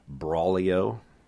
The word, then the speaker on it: Braulio: His first name is Braulio, B-R-A-U-L-I-O.